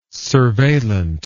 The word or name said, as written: surveillant